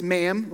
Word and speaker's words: ma'am: After that, it's ma'am, rhymes with ham, until you leave, then it's Your Majesty again.